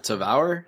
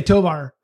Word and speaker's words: Tavour: I've been pronouncing that wrong this whole time. Tavour? – I'm not educated on it. I always say Tovar.